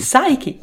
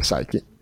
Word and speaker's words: Psyche: Psyche! – Non si dice neanche la P.